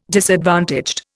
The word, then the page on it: disadvantaged: Indian